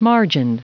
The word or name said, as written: margined